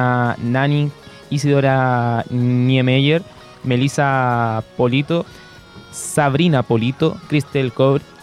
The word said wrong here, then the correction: Kobrich: eh, Cristel Kobrich, ¿lo dije bien? – Tomás Barrios, Francisca Crobeto.